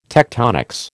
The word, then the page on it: tectonics: American English